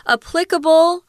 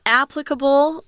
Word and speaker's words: applicable: Applicable or applicable. They're both uh, correct pronunciations. There are two there that you can choose applicable, applicable, applicable.